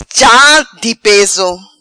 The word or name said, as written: Cha Di Peso